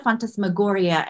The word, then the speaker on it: phantasmagoria: What's phantasmagoria? Am I pronouncing yeah. – that right? – Yeah, absolutely. Phantasmagoria.